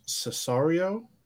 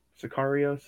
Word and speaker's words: Sicario: I think it's pronounced Cesario. Am I remembering that wrong or right? – Sicario.